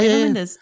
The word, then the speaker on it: Mendes: Mendes.